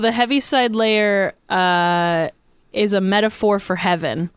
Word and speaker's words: Heaviside Layer: Heavenside Lair? No, what, describe to me what this is. – So it's what it sounds like. It's Heaviside Layer is how it's pronounced. It's spelled Heaviside space Layer.